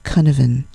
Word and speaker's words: Cynefin: His name, it's spelled C-Y-N-E-F-I-N and it's pronounced Cunavan.